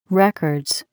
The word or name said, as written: records